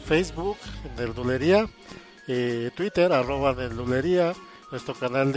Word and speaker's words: Nerdulería: Facebook, Nerdulería, Twitter, arroba Nerdulería, YouTube, es Nerdulería con acento en la I.